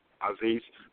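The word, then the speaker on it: Aziez: So my first name is spelled Aziz, and it's spelled A-Z as in zebra, I-E-Z, Aziz, Fresh Start Robinson, That's my Facebook page.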